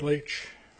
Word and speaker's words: Laetsch: Eric Leach. L-A-E-T-S-C-H.